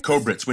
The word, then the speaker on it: Kobritz: Like when what is it? Koblitz? How do you pronounce the babysitter's name? – Kobritz.